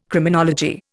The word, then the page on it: Criminology: Indian